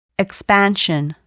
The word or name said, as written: expansion